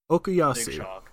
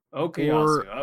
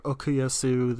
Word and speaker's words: Okuyasu: Okuyasu. – Okuyasu, okay. – Or Okuyasu, they seem to kind of go back and forth on it.